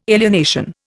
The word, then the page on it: alienation: Indian